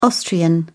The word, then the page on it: austrian: British English